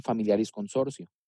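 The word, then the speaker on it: Familiaris Consortio: Y esto lo, menciona Juan Pablo II eh, durante su pontificado ya en Familiaris Consorcio.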